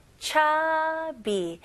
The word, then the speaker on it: chubby: 발음은 cha, 추 아니에요. chubby, chubby.